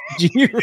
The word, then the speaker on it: gyro: It's pronounced Euro. It's pronounced Euro.